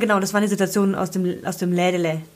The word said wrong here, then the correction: Lädle: Lädle. Aus dem Lädle. Lädle, habe ich hat das richtig gesagt? Lädle, ja. Tante Emma Lädle.